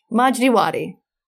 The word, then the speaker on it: Majdi Wadi: It's called Honestly with Barry Weiss, and her first interview was with a guy named Majid Wadi. Majid Wadi? How do you pronounce it? – Majdi Wadi. Yes.